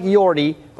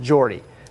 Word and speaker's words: Jordy: It looks like Jordy, but it's pronounced Jordy.